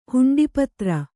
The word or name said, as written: ಹುಂಡಿಪತ್ರ